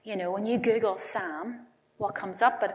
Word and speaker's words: Psalm: They've named the child, now not Sam as in Samuel, S-A-M, but Sam as in, I'm going to have to check how to spell it here, P-S-A-L-M. Sam. Sam West.